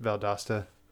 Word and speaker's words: Valdosta: Also, it's pronounced Valdosta.